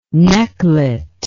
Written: necklet

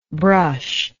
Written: brusher

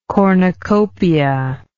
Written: cornucopiate

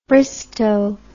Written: Bristow

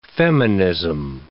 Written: feminism

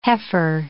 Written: heifer

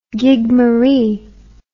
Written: gigmaree

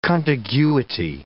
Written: contiguity

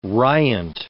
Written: riant

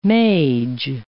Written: mage

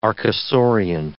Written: archosaurian

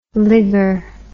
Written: ligger

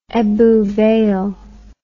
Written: Ebbw Vale

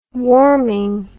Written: warming

